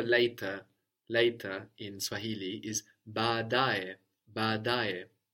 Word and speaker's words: baadae: That's B-A-A, and you pronounce both, D-A-E. And we've seen already in two examples, like njo, come, the order, and also I say, no, I say, gone into Swahili, that when we have two vowels together, they count like two syllables, no? So we have the accent on the penultimate one, on the da, badae. But we still have two at the beginning, so we pronounce both A's at the beginning, but the da is still accented.